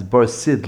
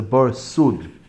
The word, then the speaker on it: bar sud: Very often it's pronounced as bar sid, like lime that protects a bar that there should be no sipish. But the word over here in the Mishnah is a bar sud.